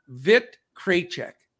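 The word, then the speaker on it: Vít Krejčí: One of our listeners sent me a pronunciation. Vit Krajicek.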